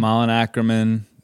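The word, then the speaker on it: Malin Akerman: am I saying that right? Malin Ackerman? – Yep. Malin Ackerman, Vanessa Lachey, Brent Morin.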